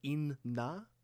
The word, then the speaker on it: Inna: I haven't thought about this, but it kind of has like a Japanese thing going there, that first word, because it's I-N-N-A, but you have to kind of pronounce both N's individually. Inna.